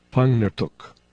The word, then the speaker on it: Pangnirtuq: An exception occurs in words like, I'll spell it P-A-N-G-N-I-R-T-U-Q, because in Inuktitut, N-G is a single consonant. The pronunciation is Pangnirtuk, which translates to place with mature male caribou.